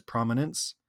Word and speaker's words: prominence: He swallows the middle syllable. So he's like, who used to be a winner, who enjoyed a life of prominence and position.